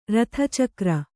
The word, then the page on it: ಋತುಚಕ್ರ: řtucakra